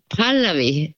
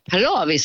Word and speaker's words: Pallavi: För den här Pallavi heter det liksom hela familjen. Men Pallavi sa hon på radion.